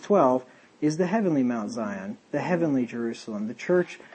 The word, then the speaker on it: Zion: And I looked, and lo, a lamb stood on the Mount Zion, I think the S is pronounced as a Z, I'm pretty sure, Zion.